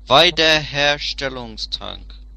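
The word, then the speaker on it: Wiederherstellungstrank: Weiderherstellungstrank. You'd always pronounce W's in German as V's.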